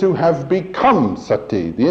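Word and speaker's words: sati (सती): The Sanskrit word for to be, the root is sat, S-A-T. The feminine form, put a long I on the end of it, and you pronounce it sati.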